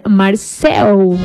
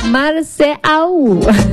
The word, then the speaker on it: Marceau: Esto rinde homenaje a este arte escénico y conmemora al actor Marcel Marceau. Marceau. Ahora sí lo dije bien.